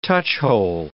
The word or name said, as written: touchhole